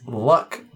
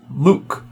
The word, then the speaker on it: Luc: "You pronounced Luc Besson's name as Luck, not Luke." Wait, is it Luck? Or is it Luke? – It's Luke.